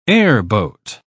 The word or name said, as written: airboat